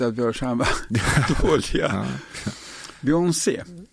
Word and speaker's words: Beyoncé: Du vet inte vilket av dessa två uttal som egentligen är det rätta. – Beyoncé eller Beyoncé? – Beyoncé? – Nej. Det är Beyoncé.